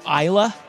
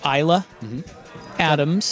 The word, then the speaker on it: Eila: It's spelled E-I-L-A, so I'm going to go Ila. – E-L-I-A? – No. E-I-L-A. So I'm going to go Ila Adams.